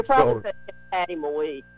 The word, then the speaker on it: Moise: Patty Moise is who I'm trying to think of. – No, Moe. Moe. – Moe? – Patty Moe. – I've been pronouncing it wrong for 40 years, 30 years.